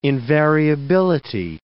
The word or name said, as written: invariability